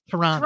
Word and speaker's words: Toronto: You don't really have the second T. It's Toronto.